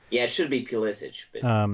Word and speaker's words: Pulisic: Well, he's come out and said he wants to be called Pulisic. – Pulisic. All right. – Yeah, which is like the Americanized pronunciation.